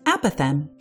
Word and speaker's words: apothegm: The word is apothem. That's spelled A-P-O-T-H-E-G-M.